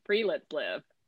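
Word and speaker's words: friluftsliv: So it's pronounced free. live.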